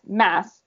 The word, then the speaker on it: Mads: His first name is pronounced Mass, just like the end of Christmas.